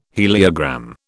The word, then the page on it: heliogram: British English